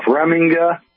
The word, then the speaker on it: Remiga: Close enough. Remiga. – Remiga. Okay. – Yeah, you just got the, you just got that accent, so.